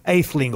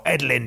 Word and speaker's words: Aetheling: William Aetheling or Edlin, depending on how you pronounce it.